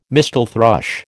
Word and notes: mistle thrush: American English

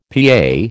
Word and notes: PA: American English

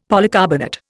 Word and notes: polycarbonate: Indian

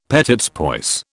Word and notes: petits pois: British English

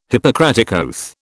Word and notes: Hippocratic oath: British English

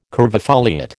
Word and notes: curvifoliate: American English

